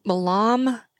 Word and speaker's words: Milam: I got this whiskey, the Milan and Green. Is that what it is, right? Did I say that right? – Milam and Green.